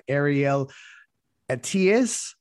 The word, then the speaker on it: Ariel Attias: And then we also have Ariel Atias.